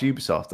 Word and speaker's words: Ubisoft: I just hate Ubisoft, which is pronounced Ubisoft, and I need to remember that.